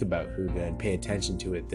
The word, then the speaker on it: hygge: Hygge is this Danish word. It's pronounced hygge, but it's spelled H-Y-G-G-E.